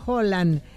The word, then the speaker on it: Haaland: Ya que hablan de pronunciación, Haaland se pronuncia Holland. Pero ya todos le dicen Haaland, ya fuera de Escandinavia.